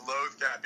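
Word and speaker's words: Loth-cat: Notice that while I researched and think that the appropriate pronunciation is loth cat because they refer to loath wolves in Rebels.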